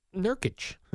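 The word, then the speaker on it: Nurkić: They say the thing that really set Yusef Nurkic off was mispronouncing his name three times? – Nurkic. – Well, you got it now.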